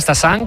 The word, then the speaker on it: Stassin: A pronunciarlo bien, Stassin, como nos han apuntado, y Félix Correia, como dice José, con muy buena pronunciación portuguesa.